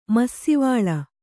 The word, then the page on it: ಮಸ್ಸೀವಾಲ: massīvāla